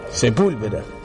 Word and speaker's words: Sepúlveda: De Luis Sepúlveda. – Sepúlveda. Tiene acento en la U.